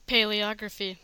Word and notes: paleography: American English

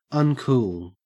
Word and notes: uncool: Australian English

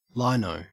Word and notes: lino: Australian English

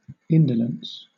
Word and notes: indolence: British English

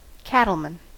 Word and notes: cattleman: American English

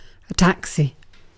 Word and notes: taxi: British English

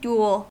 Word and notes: duel: American English

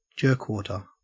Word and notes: jerkwater: Australian English

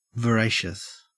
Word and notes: voracious: Australian English

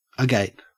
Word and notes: agape: Australian English

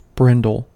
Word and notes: brindle: American English

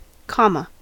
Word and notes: comma: American English

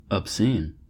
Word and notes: obscene: American English

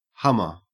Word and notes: hummer: Australian English

